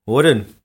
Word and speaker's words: Hoorn: Horen. Ja, zo spreek je dat in principe daaruit, ja. Ja, dat klopt wel.